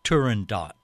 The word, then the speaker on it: Turandot: Nobody in our adorable little one-horse town knows whether Turandot is pronounced with a long or a short O, or if the T is voiced or silent, so I write the opera critic Anthony Tomasini at the New York Times, and he says that the vast majority of people in the opera world pronounce the character's name as Turandot, like Spot.